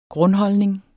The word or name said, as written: GRUNDHOLDNING